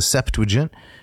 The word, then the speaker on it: Septuagint: Notice three syllables. The Septuagint, I learned that from Rich Weavers, because it's quite interesting, and then you can maybe read the translation.